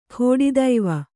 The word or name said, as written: ಕೆಡೆತಿವಿ